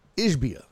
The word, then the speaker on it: Ishbia: It, Ishiba, was it? How do you pronounce his name? Ishiba. Yeah, was it Matt Ishiba? Did I get that right? Yeah, Ishbia. Yeah, I always fuck it up.